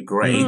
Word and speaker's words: Grey: Just easy, just search Jerry Gray. In fact, probably a good idea. My name is spelled G-R-E-Y, Jerry Gray.